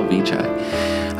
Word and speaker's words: Vichai: You do pronounce the CH in there, as far as I can tell, Vichai.